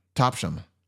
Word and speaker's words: Topsham: P-O-R-T-S. – Not mouth. – No. – T? – T-O-P-S-H-A-M. Topsham. – Topsham. You don't pronounce the H.